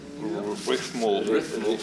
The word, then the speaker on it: Rithmul: Rithmul.